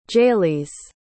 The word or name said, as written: jalees